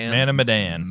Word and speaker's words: Man of Medan: Man of Medan.